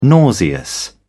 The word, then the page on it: nauseous: British English